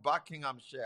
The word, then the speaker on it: Buckinghamshire: I hope I said it right. I'm not butchering the Queen's language. – You tried. – Welcome. And so what's your question? – Bless you, PT, and bless you, beautiful Pastor Chipo. So, yes, you did say it right. It is Buckinghamshire.